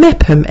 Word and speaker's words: Meopham: I mean, Mepham, M-E-O-P-H-A-M.